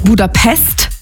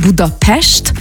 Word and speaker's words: Budapest: Also erstens appreciaten wir das schon mal, wenn man nicht Budapest sagt, sondern Budapest, weil das S immer ausgesprochen wird wie ein Sch.